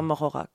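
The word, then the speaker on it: Moreaura: More comme plus en anglais, M-O-R-E, et aura comme Laura. Et on l'entend voilà. Morora comme la Morora.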